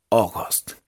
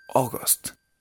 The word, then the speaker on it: August: Entonces, tengan esos trucos en la mente para acordarse que la A y la U suena como una O abierta. Y agosto se pronuncia August. O, August. August.